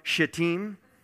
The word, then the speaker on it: Shittim: From Shittim, as spies, saying, go, view the land, especially Jericho.